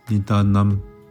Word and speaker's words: Nidanam: 尼陀那它的梵语读作 Nidanam，藏文是 Lensi。